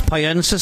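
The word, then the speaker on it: Pollensa: That's Poyensa, spelt with a double L.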